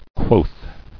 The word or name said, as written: Quoth